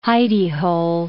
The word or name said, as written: hidy-hole